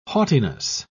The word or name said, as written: haughtiness